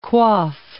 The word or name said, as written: quaff